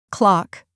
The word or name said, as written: clock